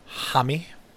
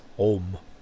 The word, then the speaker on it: Homme: how to pronounce his name. In Europe, it's Homme. And in America, it's Homme.